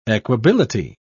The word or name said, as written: equability